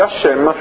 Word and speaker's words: Hashem: In un contesto non liturgico, quindi dove non è necessario pronunciare il suo nome, lo si scrive, lo si pronuncia semplicemente Hashem.